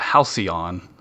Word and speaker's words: Halcyon: I've been struggling with making sure that I say Halcyon correctly. So I'm proud. I was like, am I going to say Halcyon? But I said Halcyon. – That's usually how I say it.